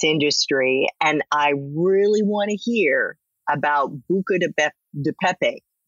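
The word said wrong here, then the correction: Buca di Beppo: Did I say that right? – Buca de Pepo.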